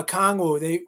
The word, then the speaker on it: Okongwu: Yeah, Okongwu.